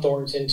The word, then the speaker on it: Thornton: So, yeah, Dohn Thornton, T-H-O-R-N-T-O-N.